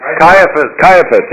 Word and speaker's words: Caiaphas: Caiaphas.